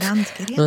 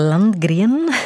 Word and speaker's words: Landgren: Nils Landgren, zeg ik dat goed? – Landgren. – Landgren.